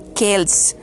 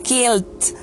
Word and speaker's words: Celts: En realidad se debe pronunciar, se dice en inglés kelts, kelts, Esta palabra proviene del griego keltoi, que significa bárbaros, y se pronuncia correctamente como keltz.